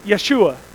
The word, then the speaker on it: Yeshua: It's pronounced like this, Yeshua. And that's pronounced just like Jesus.